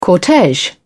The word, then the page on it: cortege: British English